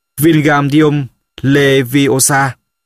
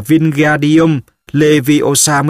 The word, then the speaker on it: Wingardium Leviosa: Ở bàn bên cạnh, Ron cũng không may mắn gì hơn, nó vung vẩy cánh tay như cánh quạt gió, đọc to: Wingardium Leviosa. Harry nghe Hermione cào nhau: Bạn đọc sai rồi, bạn phải đọc là Wingardium Leviosa mới đúng, kéo dài chữ G ra một cách rất là duyên dáng.